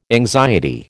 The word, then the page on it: anxiety: American English